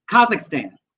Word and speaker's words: Kazakhstan: Kazakhstan.